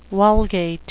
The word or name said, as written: vulgate